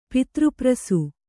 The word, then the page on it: ಪಿತೃಪ್ರಸು: pitř prasu